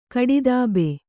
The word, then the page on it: ಕಡಿದಬ್ಬೆ: kaḍidabbe